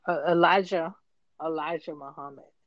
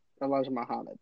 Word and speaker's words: Elijah Muhammad: How do you say his name again? I keep on Elijah Muhammad. – Elijah Muhammad. Okay.